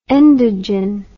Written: endogen

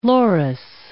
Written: loris

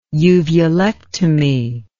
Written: uvulectomy

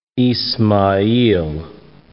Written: Izmayil

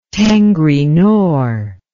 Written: tengri nor

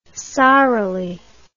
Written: sorrily

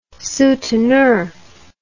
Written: souteneur

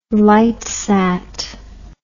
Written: lightsat

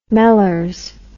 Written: mellors

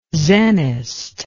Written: zenist

